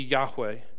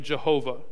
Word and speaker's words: Yahweh: In our English Bible, it's all caps, standing for the tetragrammaton, or the four consonants, YHWH, or our best guess at the pronunciation would be Yahweh. Some translations might say Jehovah or Lord. Now, Jehovah is the four letters, the consonants with the vowels from Adonai placed over top of them to help not mispronounce or speak the name of God irreverently. And so it would become out Jehovah, and there's no J in the sound.